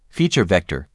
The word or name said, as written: Feature vector